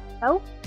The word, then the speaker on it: Dachau: Dachau.